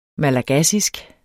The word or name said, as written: malagassisk